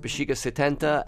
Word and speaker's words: Bixiga 70: So the it looks like Bixiga 70 is pronounced Bixiga 70, and the song is Grito de Paz?